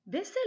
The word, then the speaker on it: baisser: Baisser les bras se traduit par to give up Faites attention à bien prononcer le son se, baisser, car baiser, avec un seul S, veut dire quelque chose de complètement différent.